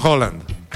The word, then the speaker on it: Haaland: El mismísimo actor de Spider-Man, Tom Holland, le mandó un mensaje al vikingo mayor, Erling Haaland, que en noruego se pronuncia Holland también como el de Tom.